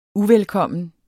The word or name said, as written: UVELKOMMENT